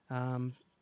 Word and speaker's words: Patreon: And again, on the Patreon, on the Dungeon Masters Block Patreon. I never know how to pronounce that because it's, you're becoming patrons. – Patreon. – It's your patronage that we are accepting.